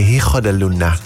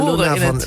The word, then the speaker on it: Hijo de Luna: Nu probeer ik dit goed uit te spreken. Hijo de Luna. Zeg ik dat goed? – Ik denk het wel, maar we gaan het zo horen. – Hijo de Luna van Meccano.